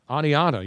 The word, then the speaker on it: Oneonta: He owned a sporting goods store in I don't know how to pronounce this. Oneonta? – Oneonta, yes.